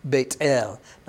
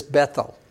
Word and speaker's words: Beit El: Now, I know your English Bible says Bethel. That's fine if you want to pronounce it that way. In Hebrew, remember, they don't have the TH sound like thee or those or these. They don't have that sound, but you pronounce the T as T instead. So Beit El is how you would say that.